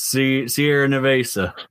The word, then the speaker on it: Sierra Nevada: It's just a just one of these Sierra Nevada. – You said that perfectly. Didn't butcher that pronunciation.